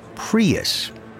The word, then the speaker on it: Prius: Prius.